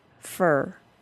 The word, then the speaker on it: for: The pronunciation of for changes to fur. Just like all the other schwa plus R words, I will go straight from the consonant sound to the R sound. The reduced pronunciation of the word for, which sounds like fur, will rhyme with her and stir and blur.